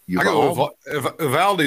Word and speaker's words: Uvalde: How do you say the name of that city? – Uvalde is what they've been saying on TV.